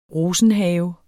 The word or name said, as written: ROSENHAVEN